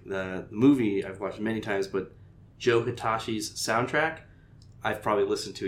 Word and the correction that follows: Hisaishi: You mean Hisaishi.